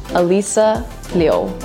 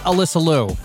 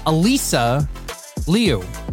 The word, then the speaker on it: Alyssa Liu: Yeah, so technically, this is how my family says my name. Alyssa Liu. That's technically how you say my name. – Yeah, she just said Alyssa Liu. Alyssa Liu. Not Alyssa Liu. Alyssa Liu. I gotta practice that.